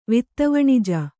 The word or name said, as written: ವಿತ್ತವಣಿಜ